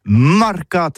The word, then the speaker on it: marcat: Eh bien, d'ici à tout, le mercat de l'Uni ou le mercat des rebelles, mais en gascogne toulousain, c'est prononcé les marcat avec un A marquette. En gascogne toulousaine, du côté par exemple de l'île des Bâches, c'est l'île Jourdain, pour parler du marché, vous prononcez les marcat.